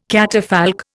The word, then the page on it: catafalque: Indian